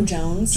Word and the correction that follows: Jonas: Joe Jonas, sorry.